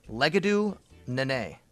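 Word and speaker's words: Legedu Naanee: No, it's this is how you say his name. Legadu Nene.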